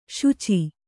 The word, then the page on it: ಶುಚಿ: śuci